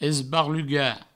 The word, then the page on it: esbarlugar: esbarluga; provençal